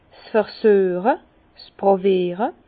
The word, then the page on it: 's Versüeche, 's Pràwiere: Bas-Rhin; Herrlisheim